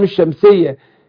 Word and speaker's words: الشمس: فهنا هنا الراء مشددة في الاثنين عشان ادغام اللام، اللي هي اللام الشمسية اللي زي يعني بيسموها شمسية عشان ما اجي انطق الشمس، بقول الشمس ما بقولش الشمس. فهنا اللام الشمسية مدغمة في الشين فمش بتتنطق، بخلاف اللام القمرية اقول القمر، ما ينفعش اقول القمر، القمر تنفع؟